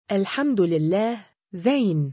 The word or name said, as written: الحمد لله، زين.